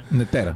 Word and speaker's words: Нетера: В интернета може да се види в Нетера, така ли се казваше, или Нетера? – Нетера. – Да, винаги бъркам ударението.